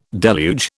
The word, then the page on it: deluge: British English